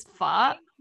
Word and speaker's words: pho: And like they commonly eat, is it pho? Did I pronounce it correctly? And like, yeah, it does seem like Vietnamese food consists of like very comforting dishes. So like, and they always look good. I always see them and like everywhere, they always look good and like very comforting to eat. Yeah. – Yeah, so that's your pronunciation is actually pretty good.